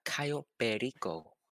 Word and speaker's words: Cayo Perico: Actually, it's pronounced Cayo Perico. – Okay. – So I would appreciate it if you got that correct every time you said the name, please.